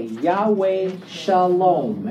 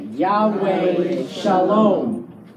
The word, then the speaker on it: Yahweh Shalom: And it's pronounced exactly that way. Yahweh Shalom. Everybody say that. Yahweh Shalom.